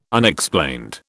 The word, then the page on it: Unexplained: British English